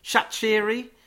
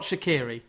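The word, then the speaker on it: Shaqiri: Shachiri, it's not Shakiri. It's yeah. a different pronunciation.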